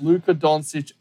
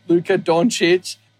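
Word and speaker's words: Luka Dončić: Like, he's the man, no one is on the same level as Luka Doncic at this point. Sorry, let me say it right so you don't yell at me. Luka Doncic at this point in his career.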